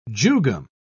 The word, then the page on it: jugum: British English